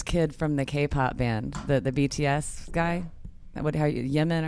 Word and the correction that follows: Jimin: or however you say his name? – Ali. – No, Jimin. Jimin, right.